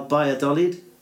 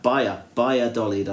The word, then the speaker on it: Valladolid: Starts with a V, but it's pronounced B. Valladolid. Yeah, it, it, it looks like Valladolid, but it's not in it. – It's hmm. supposed to be Vi- Baya Dolid, I think.